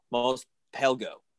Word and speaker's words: Mos Pelgo: And so he is looking for more Mandalorians, and he hears that there's some off in Mos Pelgo. – Yes. – Did I say that incorrectly? – No, you're good.